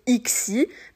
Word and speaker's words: Ixie: c'est tout simple, euh, ça se prononce, voilà, Tirgot et Ixi, il n'y a pas de, de grandes difficultés, vous le prononcez à la française et tout ira bien.